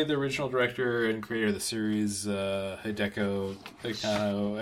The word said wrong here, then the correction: Hideaki Anno: I believe how it's pronounced. – Hideaki Anno.